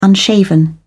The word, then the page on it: unshaven: British English